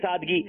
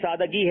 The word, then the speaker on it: سادگی: سادگی نہیں ہے, دال پہ جزم نہیں ہے بلکہ دال پہ زبر ہے. اور یہ ویسے ایسا زیادہ مشہور, اس کو کہیں گے اتنا مشہور ہے سادگی, لیکن یہ لفظ سادگی ہے.